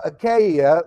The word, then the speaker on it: Achaia: Okay, now Achaia, like I said earlier, but that's the correct pronunciation now, is the Roman province in southern Greece.